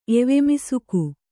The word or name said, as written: ಎವೆಮಿಸುಕು